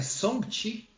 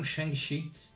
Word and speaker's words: Shang-Chi: Quer descobrir não, que a pronúncia é Song-Chi? e não um Shang-Chi.